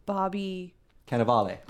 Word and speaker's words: Cannavale: I went with, I'm going to say his name wrong, Bobby... – Cannavale.